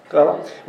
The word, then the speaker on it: Quelle: Mark, who was the earliest gospel, written probably about 60 A.D., and then Q, the German word Quelle, is that how you pronounce it? Quelle? Quelle? – Yeah. – Quelle, which means source.